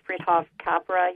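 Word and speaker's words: Fritjof Capra: Remember Friedhof Capra, you know, the whole physics thing that sort of a combination of physics and mysticism. I may be mispronouncing his name, but... – You're right, yeah. Yep.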